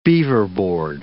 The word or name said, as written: beaverboard